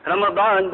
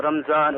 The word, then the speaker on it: Ramadan: Ramadan, the ulama mentioned that the word Ramadan, the correct pronunciation of Ramadan is Ramadan. بفتح الميم, meaning with the fatha or the zabar or the nasab of mim, Ramadan. Not as some people say Ramadan or Ramzan or Ramjan or...